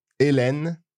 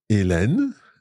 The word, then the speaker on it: Hélène: Hélène. – Hélène. So that's E with the E acute, then Len with E grave. Hélène and a silent E at the end.